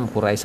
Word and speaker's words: Quraisy: Jawaban Aura ini kemudian diinformasikan pada orang-orang kafir Quraisy. Quraisy. Tapi enak bacanya Quraisy saja ya untuk kedepannya.